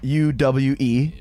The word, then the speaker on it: Uwe: Uh, he was talking about Uwe, which yeah. is Uwe. which yeah. is spelled U W E. Right. For, if it was not German. Uwe.